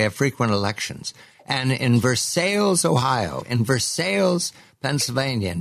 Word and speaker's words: Versailles: The reason that people say Versailles rather than Versailles is the early settlers of this region were Scots Irish, and they have a habit of pronouncing the emphasizing the second syllable as opposed to the first. So that's how you get Versailles.